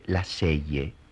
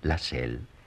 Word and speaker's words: Lasselle: Se escribe Lasselle. Se pronuncia Lassell. Felipe tenía razón. Y su hijo Héctor se puso colorado, porque él también sabía que se pronunciaba Lassell.